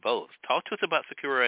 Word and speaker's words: Sakura: Sakura, yeah, that's right.